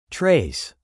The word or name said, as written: trace